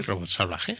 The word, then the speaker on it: Robot Salvaje: Sí, Robot Salvaje.